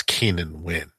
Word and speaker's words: Keenan Wynn: By the way, it was Keenan Wynn. You pronounced it wrong, but uh, oh, I don't know.